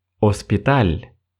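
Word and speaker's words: hospital: Другое слово. Оспиталь. Больница. Оспиталь. Точно так же в этом слове первая буква «H», но мы ее не произносим.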